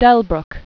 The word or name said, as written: Max Delbruck